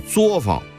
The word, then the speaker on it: 作坊: 但是呢，到这儿您就得念桌了啊，有一个词儿叫作坊啊，不是作坊啊，所以我也经常听到有人念作坊啊，是不对的啊，应该是念作啊，就是作坊啊。